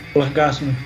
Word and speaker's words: L'Orgasme: Direto da França, Maajun com L'Orgasme, afinal, uma música que se chama Orgasmo, ela merece ser pronunciada direitinho na sua língua original, certo, meu velho? – Caramba, meu, ô louco, hein?